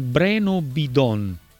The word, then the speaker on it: Breno Bidon: Dice qualcosa su Breno Bidon, io non so se si pronuncia così o meno. – Si pronuncia così, mostra di essere lui un intenditore di calcio, perché Breno Bidon è veramente uno dei ragazzi più interessanti.